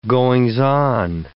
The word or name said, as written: goings-on